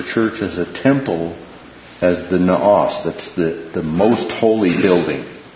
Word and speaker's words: naos: One is what's called the inner temple, and it's pronounced the naos.